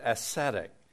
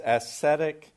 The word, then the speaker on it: ascetic: Now, one word that some people still have a hard time with is the word ascetic. It's a little bit difficult at first to pronounce, and people mix it up with beauty, aesthetics, but this is ascetic, not aesthetics.